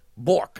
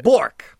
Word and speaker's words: Bourque: B-O-U-R-Q-U-E. – Bork, I would say. – Bork. – Yeah. – Dear Hoosits. – Rhymes with jork.